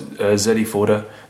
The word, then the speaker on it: Zedi Forder: Some people call us Zedi Forder. They're wrong. It's Zedi Forder.